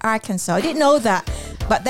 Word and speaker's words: Arkansas: So I've googled it. So it's pronounced as Arkansas.